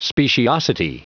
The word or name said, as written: speciosity